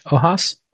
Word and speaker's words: Ojas: O-J-A-S, thinking the J is an H. Ojas? – Ojas. Yeah. – Oh, yes. So okay. So it's actually like a J.